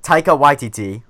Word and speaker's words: Taika Waititi: Like Taika Waititi, I practice pronouncing that. Mm-hmm. That's correct pronunciation.